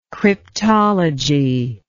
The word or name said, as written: cryptology